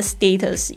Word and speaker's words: status: 那前面讲的那个 status，英式、美式都有一样的状态，就是在这个清音。